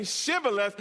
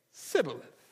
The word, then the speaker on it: Shibboleth: And so instead of saying Shibboleth, the Ephraimites would say Sibboleth.